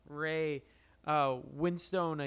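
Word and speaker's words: Ray Winstone: Even the boss battle with Ray, Winstone, I think.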